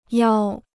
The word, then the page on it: 邀: yāo